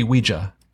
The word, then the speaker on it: Ouija: Because it's spelled Ouija. Uh-huh. That's how you say it. But I feel like most Americans say Ouija, Ouija. Uh-huh. Like it's spelled I-E. – How are we supposed to say it? – It's supposed to be Ouija.